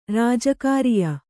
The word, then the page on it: ರಾಜಕಾರ್ಯ: Kannada